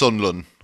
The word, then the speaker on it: Sunderland: You got to at least say it properly. It's Sunlun, all right? – Yeah.